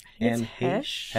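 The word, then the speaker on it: Heche: Anne Hesh.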